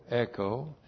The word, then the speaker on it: echo: and then the verb echo, E-C-H long O, echo.